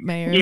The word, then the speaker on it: Maier: Mayer? Like John Mayer? – Yeah. – Oh my God. – Well, you say it like that, but it was spelled weird. I remember how it was spelled. It was M-A-I-E-R, Mayer.